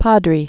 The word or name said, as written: Padre Island